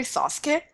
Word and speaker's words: Sasuke: That other main character, Sasuke, and since I've heard you butcher his name before, I am giving you pronunciation, literally Sasuke.